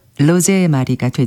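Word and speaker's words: Rosemarie: 그래서 뭐 영어로 표현하자면 로즈마리이겠지만 이제 독어식 발음으로 하면은 로제마리가 되죠.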